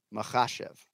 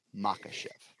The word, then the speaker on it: Makhachev: Anytime you see KH, it's a Makhachev. – Right? – Not Makhachev.